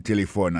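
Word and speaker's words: telephone: Téléphone, c'est comme ça, on dit téléphone en anglais. Nous devons répéter encore pour nous, téléphone. N'a pas remarqué que l'accent tonique l'a tombé sous premier syllabe là.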